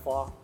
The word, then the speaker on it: FAW: It's pronounced FAW, but it's F-A-W are the letters. – F-A-W.